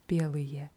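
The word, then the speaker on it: белые: Mhm, wieder diese Endung oje, die eher wie a ausgesprochen wird. Belije. – Weiße, also Plural. – Genau, Belije.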